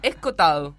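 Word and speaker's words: Escohotado: ¿Acobotado? – No, Escotado, pero con una H en el medio que Escotado. Te entrevera la... la pronunciación.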